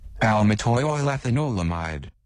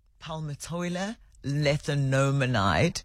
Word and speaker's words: Palmitoylethanolamide: Palmitoylethanolamide. – Palmitoylethanolamide.